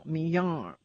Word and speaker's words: Mignon: And And I kept calling her Mignon, Mignon. And I just never could say her name right. And finally he goes, look, her name is pronounced like the steak. Mignon, Mignon.